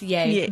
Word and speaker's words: Ye: Is that how you say it? – It's Ye. – Yay.